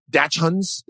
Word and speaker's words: dachshund: I don't think it's a hard sound. I think it's like a dachshund.